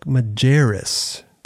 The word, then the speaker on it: Majerus: Today, we got a special guest, Alec Majerus.